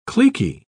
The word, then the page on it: cliquy: British English